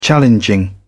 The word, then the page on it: challenging: British English